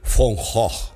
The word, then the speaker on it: Van Gogh: en el Van Gogh que decimos todos, se dice Von Gogh.